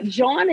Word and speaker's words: Jawn: So Jawn, as you explained, is spelled J-A-W-N, often mistaken for John, Farmer Jawn, but Jawn is J-A-W-N, and it's definitely pronounced Jawn. It's the long A.